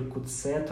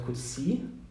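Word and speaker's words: Coetzee: и непонятно, как ее произносить. То есть у нас написано Кудзея, З там вообще нет, ну, то есть она есть в написании, но в произношении ее нету. А, в русском она всплыла, ну, потому что, понятно, она, она пишется. А так-то он а, произносится то ли Куци, то ли Куце, то ли Куци, все его произносят по-разному. Сам он себя называет Куце, как я понял из Википедии,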